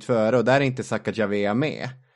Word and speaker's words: Sacagawea: Ja, uttalar Sacagawea.